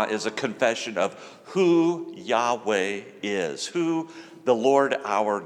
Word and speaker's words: Yahweh: And in the Hebrew language, the word Lord is pronounced Yahweh.